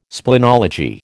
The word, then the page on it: splenology: American English